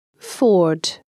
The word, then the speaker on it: Ford: Следует помнить о том, что известное название автомобиля Ford следует произносить Ford.